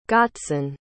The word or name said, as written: gotzon